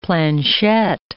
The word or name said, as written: planchette